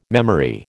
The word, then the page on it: memory: American English